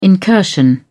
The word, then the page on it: incursion: British English